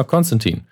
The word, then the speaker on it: Constantine: Julian hat es jetzt comicmäßig ausgesprochen, Constantine wie er sich auch immer wieder andere Leute korrigiert im Comic, dass es Tine heißt, wie Fine, Wine und Dine. und in den amerikanischen Filmen und Serien heißt es aber immer Konstantin.